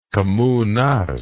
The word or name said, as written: Kommunarsk